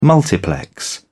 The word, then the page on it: multiplex: British English